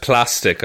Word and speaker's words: Plastique: And Plastique quickly grabs her... – pronounced plastic, I'm sorry. – Oh, for God's sake. No, it's Plastique. Plastic. Just the you know, it's just he's just a fucking tub of plastic.